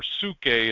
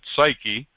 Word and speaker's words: psyche: You're going to do the best you can, but this is talking about on this earth, in these bodies, with this soul, or psuche in the Greek, which we pronounce it psyche, when this is what he's talking about, not what happens when you depart the lands of the living.